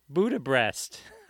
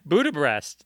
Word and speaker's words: Budapest: Budapest. I said it wrong. Budapest.